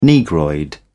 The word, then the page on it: negroid: British English